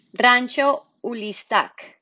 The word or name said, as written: Rancho Ulistac